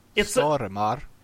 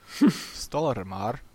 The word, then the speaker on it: Stormare: Stormare. – Peter Stormare. I can't pronounce it completely correct either, because I can't pronounce the Swedish hard R.